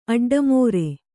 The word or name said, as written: ಅಡ್ಡಮೋರೆ